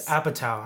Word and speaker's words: Apatow: And it's Apatow. I'm like, what? Apatow? Who's the... – Nobody corrected me. Nobody said, hey, You're saying it wrong.